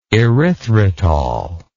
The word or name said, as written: erythritol